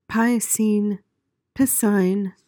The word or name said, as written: piscine